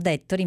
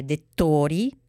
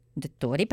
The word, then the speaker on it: Dettori: Ha avuto molto successo la mia citazione di Cavallo Magazine, però, ma guarda un po', c'è sempre un però, ho sbagliato a pronunciare il cognome di Frankie. Io l'ho chiamato Dettori, invece è Dettori. Dettori, perché è sardo,